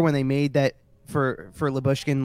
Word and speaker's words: Lyubushkin: No, like Lyubushkin. – Oh, Lyubushkin.